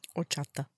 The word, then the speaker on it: horchata: Horchata. – Don't pronounce the H.